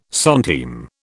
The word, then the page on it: centime: British English